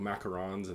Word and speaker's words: macarons: And they're kind of obsessed with like French culture too, so I bet you could like macarons and that, like all that kind of stuff. – Everywhere. – Everywhere. – Yeah. Everywhere. Oh my god. – Thank you for pronouncing that correctly, by the way.